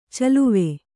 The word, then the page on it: ಚಲುವೆ: kannada